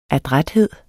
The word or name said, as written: adræthed